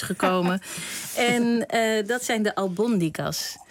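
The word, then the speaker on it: albóndigas: Ja, ze zetten in Spanje vaak eventjes een accentje op de lettergreep waar de klemtoon komt. En ook al zouden wij liever albondigas willen zeggen, want zo zijn wij Nederlanders. Maar nee, hij moet op de tweede.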